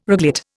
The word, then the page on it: reglet: Indian